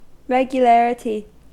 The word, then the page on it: regularity: American English